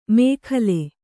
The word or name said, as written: ಮೇಖಲೆ